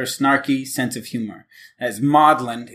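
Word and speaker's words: Magdalen: Yeah, the British, I think there's a, it's spelled Magdalene College of Oxford, but they say it Maudlin.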